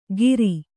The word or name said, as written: ಗಿರಿ